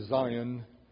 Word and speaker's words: Sion: In the New Testament, in the King James translations, it is spelled S-I-O-N. Pronounced the same, though, in English, Zion.